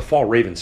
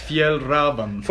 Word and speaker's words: Fjällräven: Oh, I was thinking about the Fall Raven stuff. – Yeah. – And how's it pronounced? – Fjällräven.